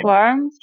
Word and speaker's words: Swarm: É Swan. Eu não sei como pronuncia. também você consegue ajudar? – S-W-A-R-M. – Swarm.